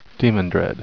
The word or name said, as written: demandred